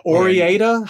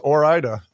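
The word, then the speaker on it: Ore-Ida: The Orieta? How do you pronounce that one? – I thought it was Orida.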